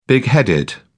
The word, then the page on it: bigheaded: British English